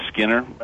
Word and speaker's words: Skinner: My last name is Skinner. I joke that you could pronounce that with a silent K because I'm a sinner.